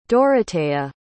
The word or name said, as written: Doroteya